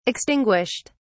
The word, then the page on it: extinguished: British English